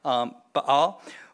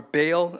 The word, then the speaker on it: Baal: Some people would say that it goes all the way back to the Canaanite god Baal, or Baal, as we pronounce it in English.